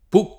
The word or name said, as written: Pooh